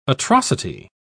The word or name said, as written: atrocity